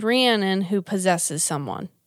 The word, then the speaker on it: Rhiannon: Rhiannon.